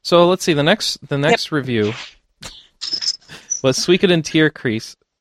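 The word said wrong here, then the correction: Tierkreis: Or excuse me, How do I keep saying that wrong? So we get into Tyrkrais, which was reviewed by Mike Menke.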